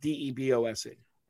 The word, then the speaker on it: DeBose: Ariana DeBoye. Am I saying that right? West Side Story? They just have DeBose.